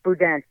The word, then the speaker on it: boudin: I always mispronounce it, but boudin. – Yeah, boudin.